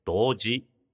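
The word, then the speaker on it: 同事: 動詞とは同級生の動に、事実の字と書いて動詞と読みます。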